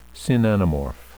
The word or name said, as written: synanamorph